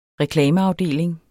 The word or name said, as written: reklameafdeling